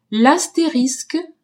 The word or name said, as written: astérisque